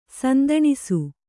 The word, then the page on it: ಸಂದಾನಿಸು: sandānisu